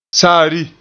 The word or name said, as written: saari